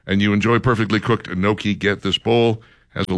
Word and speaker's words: gnocchi: G-N-O-C-C-H-I. Gnocchi? – I've always heard it pronounced gnocchi.